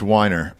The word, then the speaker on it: Weiner: If they don't like the way it sounds, so like the creator of Mad Men, Matthew Weiner, and when he's like, Weiner. No, it's Weiner. And I'm just like, no, the fuck it isn't. – It's Weiner, bro.